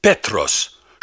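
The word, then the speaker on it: «петрос» (Πέτρος): Имя Петр по-гречески произносится как «петрос», что значит «камушек».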